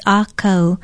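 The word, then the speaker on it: akau (ʻākau): Aloha, I'm Nilani Poliahu of Hawaii Public Radio, and our Hawaiian word of the day is akau. Akau is spelled okina e ke e u with a kahako or stress over the first vowel. Say it with me, akau.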